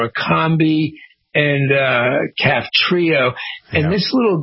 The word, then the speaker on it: Orkambi: Well, apparently there's a new drug called ORKAMBI, or Kambi, I guess is how it's pronounced.